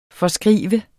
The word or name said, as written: forskrive